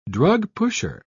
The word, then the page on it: drugpusher: British English